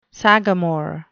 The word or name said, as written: sagamore